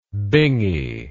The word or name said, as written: bingy